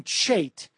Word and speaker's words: Chait: It was by Jonathan Chait, as you inform me, is how he pronounces his name, of the New Republic, What the Left Doesn't Understand About Obama.